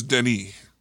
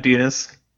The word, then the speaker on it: Denis: Well, I think his name is Denny. – Oh, Denny. – Denny, yeah. – Not Dinas. Okay.